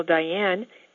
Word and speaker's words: Diane: I think you might know how to spell Diane, D-I-A-N-E. My middle name, which is Phelps, P-H-E-L-P-S, is the same as the Olympic swimmer, Michael Phelps.